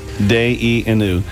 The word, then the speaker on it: Dayenu: If that was all that would have happened, and the Hebrew word for it would have been enough is Dayenu, pronounced Dayenu.